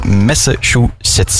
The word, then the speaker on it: Massachusetts: Ja, ik ben niet goed in dat uitspraak, ik heb al vaak fout gezegd, maar het is Massachusetts.